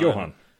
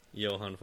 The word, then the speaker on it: Johan: Oh, no, not Forcell. Joe Forcell Dev because it's jo- Johan. – That's Johan. – Johan Forsell.